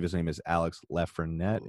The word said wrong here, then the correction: Lafrenière: if I'm pronouncing it right? Lafrenière. – Lafrenière.